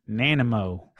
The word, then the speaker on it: Nanaimo: So Bill Petrie chimes in and he says that it's pronounced Nanimo.